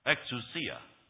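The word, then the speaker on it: Exousia (ἐξουσία): Exousia.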